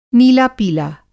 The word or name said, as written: नीला-पीला